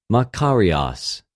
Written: μακαριος

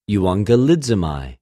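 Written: εὐαγγελίζομαι

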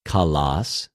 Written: καλος

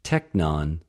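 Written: τεκνον

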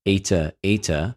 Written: εἰτε...εἰτε

